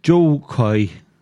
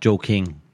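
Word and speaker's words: Jo Koy: Well, if I can pronounce it properly, it's Joe Coy. That's it. Just Joe Coy. Not Joe King.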